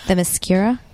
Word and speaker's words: Themyscira: Themyscira? – That one.